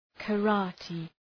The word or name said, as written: karate